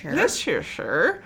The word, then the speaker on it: Leicestershire: Leicestershire. – It's not spelled like that, but I looked it up. That is how you say it.